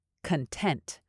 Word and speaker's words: content: It can also be an adjective, but then you say it like this, content. So in this sentence, because it's being used as a noun, you want to emphasize the first syllable.